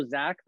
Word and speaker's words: Zach: My name is also Zach, though I spell it with an H, not Z-A-C, but Z-A-C-H, not to be confused with my older brother.